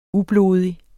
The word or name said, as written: ublodig